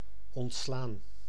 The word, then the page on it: dispenser: /dis.pɑ̃.se/